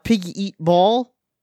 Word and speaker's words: Pig Eat Ball: I've been playing Pig Eat Ball. Pig okay. – Eat Ball. Yeah, that's how it's pronounced.